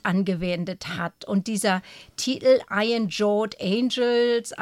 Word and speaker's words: Iron Jawed Angels: Und der Kampf, die Reihe Kampf ums Wahlrecht startet in diesem Rahmen dann schon am Donnerstag mit Iron Jawed Angels. Habe ich das richtig gesagt? – Ja, das Iron Jawed Angels,